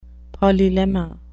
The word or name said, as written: polylemma